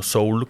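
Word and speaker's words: Seoul: Soul Vibe, pero Soul no como Soul de alma, sino Soul como Seúl, como la ciudad de Seúl pronunciada en inglés. Según yo en coreano también se pronuncia algo así como Soul, más que Seúl.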